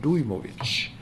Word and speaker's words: Dujmovic: Men betoningen slår på U, så han heter Daniel Dujmovic.